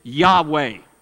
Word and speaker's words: Yahweh: J-H-W-H. It's called the Tetragrammaton. And we pronounce it Yahweh, the name of God that God gave to Moses at the bush.